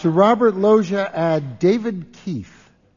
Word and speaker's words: Robert Loggia: L-O-G-G-I-A. Yeah. That is how you pronounce Robert Loggia, right? – Yeah.